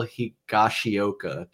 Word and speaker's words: Higashioka: and Kyle Higashioka. Did I pronounce that right, Ian? – You sure did.